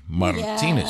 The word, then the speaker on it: Martinez: Before that, it was the Vocal Tones, Come Dance With Me, 1956, Manhattan, with Bobby Johnson and Roland Martinez. Did I say it right? Martinez. – Yes.